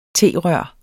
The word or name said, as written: T-rør